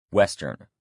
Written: western

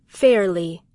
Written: fairly

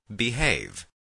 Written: behave

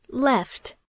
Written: left

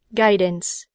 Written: guidance

